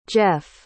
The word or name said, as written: jeff